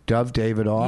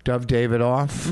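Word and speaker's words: Dov Davidoff: Dove Davidoff. – You know what? You said his name right, and I like that. – Dove Davidoff.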